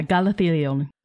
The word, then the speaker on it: Galathilion: And this one is named Galathilion. – That's correct. Yeah. – I had to read all the syllables. – Yeah, Galathilion.